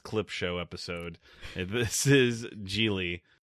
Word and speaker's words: Gigli: That's O-V-N-I-O. – Now, time for the podcast. – Gigli, or as the pronunciation on Wikipedia, Gigli, does not have rhymes with really.